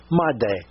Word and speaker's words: m'aidez: Actually, Maidez, M-A-I-D-E-Z, meaning "help me." – That makes sense. – Pronounced in French a little bit, "made."